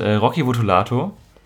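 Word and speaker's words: Rocky Votolato: damals mit Casper, Prinz Pi, Slime und Rocky Vutulato. Ja, so spricht man den aus.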